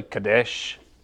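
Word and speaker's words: Kadesh (קדש): This word is, you see it there in the Hebrew, and it's basically Kadesh is how you would pronounce it.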